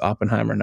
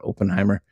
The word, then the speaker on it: Oppenheimer: And so right now it's Oppenheimer, which we've been pronouncing this wrong the whole time. I found out today it's Oppenheimer, not Oppenheimer.